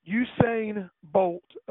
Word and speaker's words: Usain: Usain Bolt, apparently, is the correct pronunciation.